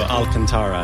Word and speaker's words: Alcantara: Alcantara, yeah. I'm probably mispronouncing that. – Nope, you're right.